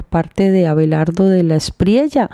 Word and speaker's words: Espriella: En realidad sería Abelardo de la Espriella. Pero como estamos en Latinoamérica, donde la doble, doble L, la Y, se pronuncia como Y prácticamente, entonces de la Espriella. De la Espriella,